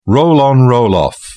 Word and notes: roll on roll off: British English

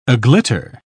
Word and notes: aglitter: British English

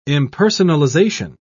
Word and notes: impersonalization: British English

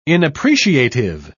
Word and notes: inappreciative: British English

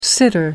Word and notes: sitter: American English

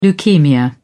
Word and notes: leukaemia: British English

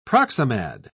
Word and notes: proximad: British English

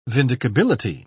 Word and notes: vindicability: British English

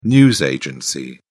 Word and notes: news agency: British English

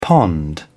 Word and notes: pond: British English

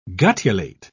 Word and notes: guttulate: British English

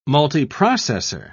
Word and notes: multiprocessor: British English

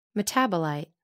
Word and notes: metabolite: American English

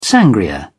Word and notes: sangria: British English

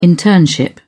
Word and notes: internship: British English

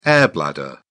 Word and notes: air-bladder: British English

